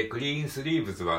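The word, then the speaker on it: Greensleeves: で、クリーンスリーブズはね、えっと、ここ S で終わってるんだけど、発音記号的にはこれは Z ってなってますね。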